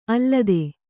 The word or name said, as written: ಅಲತೆ